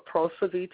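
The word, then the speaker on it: Kposivi: This particular person's name is Osumi Trontond, and her name also is Posovi, and that's K-P-O-S-I-V-I with the silent K. Now, we're going to call her Posovi today.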